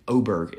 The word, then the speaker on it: Åberg: Like, these two guys at the top, Ludvig Åberg is how everybody's saying to pronounce it now,